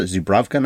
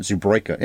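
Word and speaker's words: Zubrowka: The W is a V, right? That's how you pronounce it, Zubrovka, not Zubroika.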